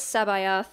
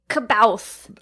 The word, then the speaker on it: C'Baoth: Rice asks if Christopher Lloyd will be playing Joruus C'Baoth in The Mandalorian. – Nice pronunciation. You didn't even ask. – I really wanted to say Kabouth.